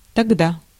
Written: là

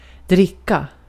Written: dricka

